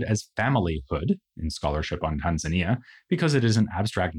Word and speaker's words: Tanzania: Nyerere and a Zanzibari leader avoided this possibility by negotiating a federation between Zanzibar and Tanganyika, giving us the new country of Tanzania, or as it is also sometimes pronounced, Tanzania.